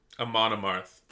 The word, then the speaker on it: Amon Amarth: So Vlad Bad is going with Amon Amarth. Am I saying that right? – Amon Amarth.